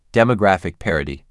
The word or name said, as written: Demographic parity